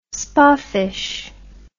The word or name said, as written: spoffish